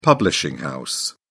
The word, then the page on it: publishing house: British English